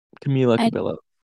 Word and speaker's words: Camila Cabello: You know Camilo Cabello? – I recognize the name. – I feel like I'm saying her name wrong. Camila Cabello.